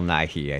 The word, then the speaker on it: Nighy: Bill Nye? – Bill Nighy, I think, Bill is Nighy? How they pronounce it, yes.